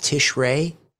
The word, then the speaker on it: Tishrei: In Ezekiel 38, Gog and Magog, that war begins in, is it pronounced Tishrei? Is that correct? – Right.